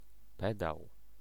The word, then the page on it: pedał: /ˈpɛdaw/